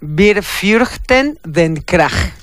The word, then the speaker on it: Wir fürchten den Krach: No, ya a esta hora no me sale. No me sale ninguna pronunciación. Wir Fürchten den Krach. Ahí estuvo.